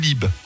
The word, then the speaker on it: Lieb: L-I-E-B, donc Lieb ou Lib. – Très bien. – Les deux versions, voilà. Il est de Pau, je sais pas si à Pau on prononce Lieb ou Lib.